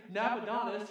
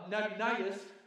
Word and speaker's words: Nabonidus: And the reality is that there was a guy named Nabonidus who was, no, I'm sorry, I, I pronounced that wrong, uh, Nabonidus, who was the last king of the Babylonian Empire, and everybody knows that.